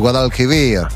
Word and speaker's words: Guadalquivir: Le Guadalquivir. – Le Guadalquivir.